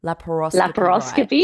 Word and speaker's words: laparoscopy: Okay, so I think now I've got the pronunciation of laparoscopy.